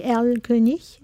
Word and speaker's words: Erlkönig: Alors, le titre, c'est Erlkönig.